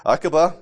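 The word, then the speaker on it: Akiva: If it was English, there'd be a U in there. Aqiba? – Aqiba. – Go cool. Well, hey. – You can substitute K sound. – Okay. Aqiba, about 100 AD, so after, this would be after the church had started.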